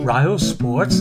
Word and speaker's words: RyoSports: Rio Sports is spelled RYOSPORTS.